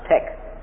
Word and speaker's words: TEK: It says T-E-K. No doubt that's pronounced Tech.